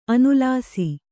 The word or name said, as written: ಅನುಲ್ಲಾಸ